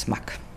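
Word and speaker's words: smac: Am Anfang hieß es bei den Chemnitzern Schocken und inzwischen sagen alle Smack, wenn man immer meint, das muss was Englisch ausgesprochen werden, aber es heißt Smack.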